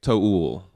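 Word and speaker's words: To Øl: We talked about this before, but a lot of people say Tool because it's just T-O-O-L. But the second O, you know, it's got the line through it. And so, I after doing a little bit of research, it seems like the proper way to say it is like Teol.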